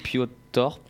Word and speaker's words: Piotr: P I O T R, euh, donc Piotr peut-être, je ne sais pas. Piotr Jasinski. Merci, voilà, c'est exactement ça.